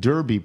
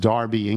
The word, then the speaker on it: Derby: Brian Pyle in Derby, probably pronounced Darby, England.